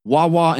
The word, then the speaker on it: Hua Hua: So we got these two monkeys, I believe pronounced Wawa and Zhang Zhang. – Okay. – H-U-A-H-U-A Wawa.